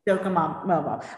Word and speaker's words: Docomomo: Today on the Practical Preservation Podcast, I have with me Docomomo US. Did I say that right? – Docomomo.